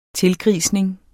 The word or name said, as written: tilgrisning